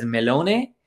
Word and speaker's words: melone: so I think Italians pronounced uh, pronounce melon as melone, so I had a melone gelato.